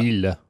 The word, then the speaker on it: Miele: Miele, I think. S7 vacuum cleaner. – It's Miele. – Miele, right. – No, Miele.